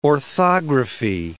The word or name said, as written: orthography